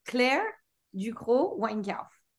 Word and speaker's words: Claire Ducrocq Weinkauf: Claire Ducrocq Weinkauf.